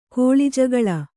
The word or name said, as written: ಕೋಳಿಜಗಳ